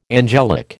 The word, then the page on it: angelic: American English